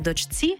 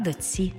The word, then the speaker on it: дочці: Пишемо «чці», а вимовляємо «ці». Наприклад, не «дочці», а «доці».